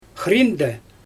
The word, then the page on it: xriindɛ: Xyzyl